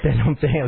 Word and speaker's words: Phnom Penh: P-H-N. – Phnom Penh, right? Phnom Penh.